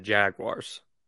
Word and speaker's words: Jaguars: I would say the Jaguars.